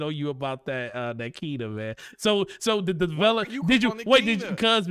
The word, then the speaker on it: Kena: It is Kena, Bridge of Spirits, not Kena, Bridge of Spirits.